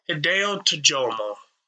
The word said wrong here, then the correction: Hideki Tojo: Japanese? – Hideki Tojo? – Hideki Tojo. – Yeah, you fucking butchered that name.